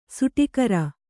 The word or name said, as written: ಸುಟಿಕರ